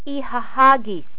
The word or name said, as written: ihahagis